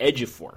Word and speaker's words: Ejiofor: His name is pronounced Ejiofor. I've been saying it wrong this entire time.